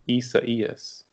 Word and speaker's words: Isaias: Yeah, so it's spelt I-S-A-I-A-S. And I think originally some of us thought it was Isaias or Isaias, but it's actually pronounced Isaias, which, as Ben said, is the Spanish-Latin derivative of the Hebrew name Isaiah.